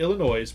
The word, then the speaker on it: Illinois: Illinois, presenting the key to the city of... – The S is silent.